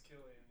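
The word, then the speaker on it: Cillian: Is it Killian? – It's Killian.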